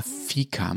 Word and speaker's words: Fika: Nicht Finca, Fika, Matthias.